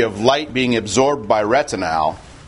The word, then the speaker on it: retinal: And this operates on the basis of a pigment called retinal. And I pronounce it that way rather than retinal, which means of the retina, because retinal is an aldehyde, ends in AL.